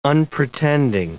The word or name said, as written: unpretending